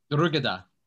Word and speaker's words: Drogheda: Drogheda. – Sure. – If it's Gaelic, it's probably got that brogue to it. So you gotta say Drogheda. – Yeah. – Drogheda.